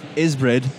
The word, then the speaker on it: Ysbryd: We're at EGX, and I'm here with Brian with Ysbryd.